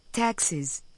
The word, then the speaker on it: taxis: La forma plural de taxi es taxis. Observe que la S del plural se escucha y se pronuncia z.